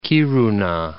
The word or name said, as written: Kiruna